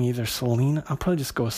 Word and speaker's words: Selene: And then when I looked it up online, it's Selene, they say it's Selene, with a long E, Selene.